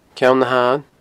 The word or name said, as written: Ceann na h-Athann